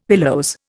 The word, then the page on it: pilose: Indian